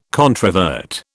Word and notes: controvert: British English

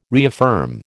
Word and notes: reaffirm: American English

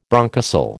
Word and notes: bronchocele: American English

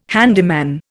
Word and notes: handyman: Indian

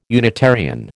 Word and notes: Unitarian: American English

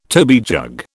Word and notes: toby jug: British English

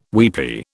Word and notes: weepy: British English

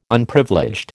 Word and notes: Unprivileged: American English

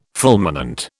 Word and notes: fulminant: British English

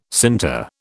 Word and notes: sinter: British English

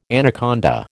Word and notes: anaconda: American English

